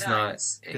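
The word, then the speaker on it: Gdańsk: So let's see how to pronounce Gdansk. – Using Google here. – Gdansk. – What? – That is Gdansk.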